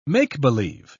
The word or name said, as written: make-believe